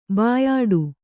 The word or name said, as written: ಬಾಯಾಡು